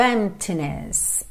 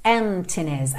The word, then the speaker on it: emptiness: Então, spend your days full of emptiness, emptiness, a gente não pronuncia a letra P. Ok? Então não se preocupe em dizer emptiness, emptiness.